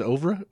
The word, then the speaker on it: oeuvre: His I never can pronounce this word. His oeuvre? – Yeah, close enough. – His oeuvre isn't complete.